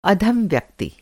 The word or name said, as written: अधम